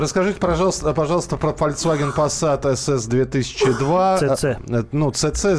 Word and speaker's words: Passat: Ну, в слове Passat тоже две буквы S. Ну да, и она, они произносятся Passat.